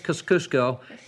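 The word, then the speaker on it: Kościuszko: That is Kosciuszko, and he is Polish.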